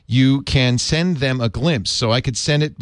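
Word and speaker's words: Glympse: And that's G-L-Y-M-P-S-E. – Glimpse spelled with a Y.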